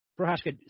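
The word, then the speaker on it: Procházka: Prochazka submitted Glover Teixeira.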